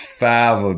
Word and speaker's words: fava: I got told by Joey, actually, it's fava beans, not faba. I was calling it faba. It's fava beans. I could be wrong. – F-A-V-A?